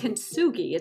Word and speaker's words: kintsugi: It is from the 15th century, and it's called kintsugi. It's spelled K-I-N-T-S-U-G-I.